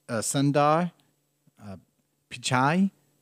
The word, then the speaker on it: Sundar Pichai: It's Sundar Pichai.